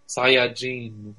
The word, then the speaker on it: Saiyajin: Right, he was using, like, I guess what you would say if the English dub had not mispronounced it, because like in the Japanese region they say Saiyajin, which the gene it means like people, mm-hmm.